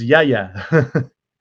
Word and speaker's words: Yahya: And then even with the Muslim community, a small group of Muslims properly pronounce Ha. So it's Yahya.